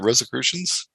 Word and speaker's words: Rosicrucians: Did I ever improve my pronunciation of the Rosicrucians? – You get it. That sounds right.